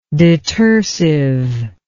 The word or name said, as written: detersiveness